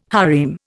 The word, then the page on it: harem: Indian